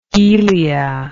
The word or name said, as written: elian